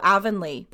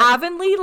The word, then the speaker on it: Avonlea: She said it's like heavenly. So Avonlea. That's how you say it. Avonlea. – I'm glad you told us that. – Avonlea like heavenly.